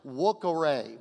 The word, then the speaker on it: vocare: So, anyway, they all jumped me, and they say the V sounds like a W in, in Latin, so it's vocare, vocare, some, something like that. Okay, just you fill in the blanks in my, I called it vocare or something like that.